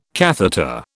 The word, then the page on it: catheter: British English